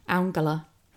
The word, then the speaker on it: Angela: I was going to say Angela Merkel, but I was going to try and say her name correctly, which is like Angela.